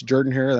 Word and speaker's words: Jordan-Hare: It's not Jordan-Hare. It's Jordan-Hare. That's how they say it down there, country people.